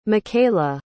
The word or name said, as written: mikala